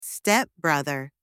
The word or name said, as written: stepbrother